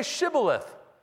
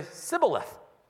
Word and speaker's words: Shibboleth: And they would say, "Well, then say Shibboleth." And the Ephraimite would say, "Sibboleth." For he could not pronounce it right.